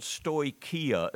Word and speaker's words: stoicheia: This word stoicheia is the way it's pronounced, and it's an interesting word in the New Testament that usually refers to things that the Gnostics did, the stoicheia.